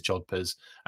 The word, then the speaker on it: jodhpurs: Yeah, okay, it is Jodhpurs, and it's spelt that way, Ryan.